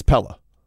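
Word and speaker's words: Pelle: It's P-E-L-L-E, but it's Pella.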